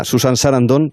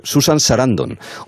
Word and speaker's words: Susan Sarandon: Era alucinante, además era especialmente meticuloso, hasta el punto de decir que si nosotros decíamos en antena Susan Sarandon, te llamaba y te decía, no es así, es Susan Sarandon.